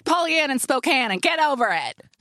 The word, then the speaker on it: Spokane: She's living her life in a city that rhymes with her own name, which is just a picture book waiting to happen. – Pollyann in Spokane?